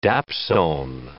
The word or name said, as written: dapsone